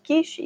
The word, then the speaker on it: quiche: En español, bueno, esta palabra viene del francés. Por lo tanto, lo pronunciamos y lo decimos igual, ¿vale? viene quiche. Lo decimos quiche y se escribe igual, ¿vale?